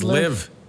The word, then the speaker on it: Liev: am I saying that right? Liev Schreiber? Or is it Liv Schreiber? – I think it's Liv.